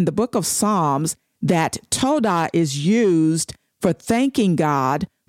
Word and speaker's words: towdah: The next word I want to talk about is pronounced toda. It's spelled T-O-W-D-A-H.